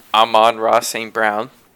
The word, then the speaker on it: Amon-Ra St. Brown: So I really liked CB's 13th round pick, Amon-Ra St. Brown.